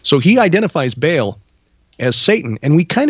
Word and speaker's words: Baal: Or for you scholars out there, just so you know, I know how to say it. It's Baal. There's a glottal stop in there.